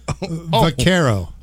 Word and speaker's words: vaquero: And your word, is vaquero. – Vaquero? – Va. – The. – Va. – V. – Va, with a V. – Yeah. – Care. – Yeah. – As in, you don't care. Oh. Vaquero. – Oh, you don't care. How do you spell it? – V-A-Q-U-E-R-O.